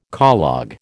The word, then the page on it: collogue: American English